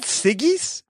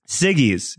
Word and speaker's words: Siggi's: Siggi's? – Siggi's, yeah, Siggi's, or or as I like to pronounce it around my household, Siggis. Uh, it's S I G G I S.